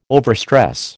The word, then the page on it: overstress: American English